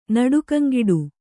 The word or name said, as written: ನಡುಕಂಗಿಡು